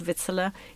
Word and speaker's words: victualler: So the vitella, so it's spelt victualler, but it's actually pronounced vitella.